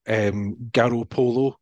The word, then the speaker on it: Garoppolo: Garoppolo, which is the correct pronunciation of his name, and that sounds like the catchphrase of the Spice Girls.